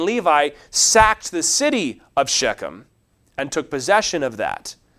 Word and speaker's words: Shechem: Shechem is how you'd pronounce it.